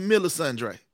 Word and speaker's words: Melisandre: It's Melisandre. – Y'all know what I call Lady Mama Line. – There it is. – The emphasis is on the sun.